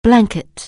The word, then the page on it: blanket: anglais